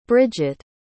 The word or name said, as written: Brigit